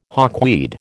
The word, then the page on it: hawkweed: American English